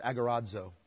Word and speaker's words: agorazo: You know, the word there for redeemed is the word agorazo. Agorazo. I'll spell it for you. A-G-O-R-A-Z-O. R-A-Z-O.